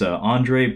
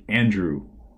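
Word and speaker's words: Andrzej: Last fella is Andre Bartkowiak. Finally got confirmation on the pronunciation of that name. I wasn't sure if it was Andrew or Andre, but it is apparently Andrew Bartkowiak.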